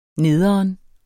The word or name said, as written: nederen